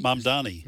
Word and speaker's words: Mamdani: How do you pronounce his last name? Mamdani? – Mamdani.